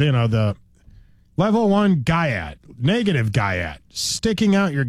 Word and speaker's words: Gyat: Now, how you spell that? – G-Y-A-T. – Oh, I was thinking Gat, like in, you know, are you strapped, you know. – But no, it's not. – No. – Yeah, that would make more sense. Gyat, it's a term that has been around for a while, and it's starting to show up with a younger white audience. – They say the Y, the A, and the T are silent. – Yeah. – Yeah, so that's how you pronounce it.